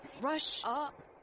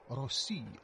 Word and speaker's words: Russia: Rush up. It's not home. – Say it correctly. Place the emphasis where it belongs. It's more like Russia.